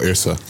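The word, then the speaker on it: Yrsa: But this book is called The Terrible by Yasa Daily Ward. – Oh, Irsa. – Is it? How do you pronounce it? – I think it's Irsa.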